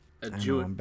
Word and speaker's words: adjutant: Adjutant.